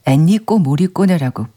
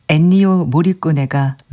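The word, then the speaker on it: Ennio Morricone: 이민하님, 일요일 날 제가 애니오 모리꼬네의 작품을 전해드렸는데 제가 애니꼬 모리꼬네라고 발음을 했답니다. 더위를 먹은 걸로. 죄송합니다. 이민하님, 애니오 모리꼬네가 맞습니다. 뒤늦게 바로 잡을게요.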